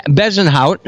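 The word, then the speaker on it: Bezuidenhout: Christian Bezenhout, I can't believe I pronounced that properly.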